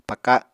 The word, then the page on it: paka: Apatani